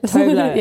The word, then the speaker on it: Tove Lo: I'm saying it wrong, aren't I? How would you say it? – No, that's right. Yeah. – Tove Lo.